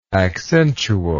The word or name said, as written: accentually